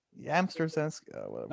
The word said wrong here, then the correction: Yastrzemski: There's no M. There's no M to start the name. You're saying Yamsterzemski is Yasterzemski.